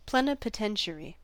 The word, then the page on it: plenipotentiary: American English